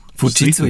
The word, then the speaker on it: Fujitsu: Fujitsu. – Fujitsu, ja. richtig. Würde ich auch so sagen.